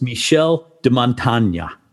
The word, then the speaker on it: Michel de Montaigne: I'm going to try and pronounce his name right. It was Michel de Montaigne. – And, oh, that was good.